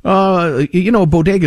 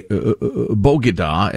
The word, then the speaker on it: bodega: Bodega is one of those, or a Bogada, as the First Lady pronounces it.